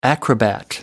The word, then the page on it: acrobat: American English